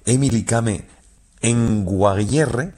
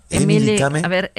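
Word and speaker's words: Emily Kame Kngwarreye: una mujer que se llama Emily Kame Nguayere, lo he dicho mal. – Emily Kame Nguayere.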